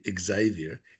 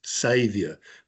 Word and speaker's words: Xavier: It's not actually Xavier. It's Xavier, because he saved them in that game.